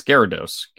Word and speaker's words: Gyarados: Scholar, how does everyone pronounce Gyarados? – It's Gyarados.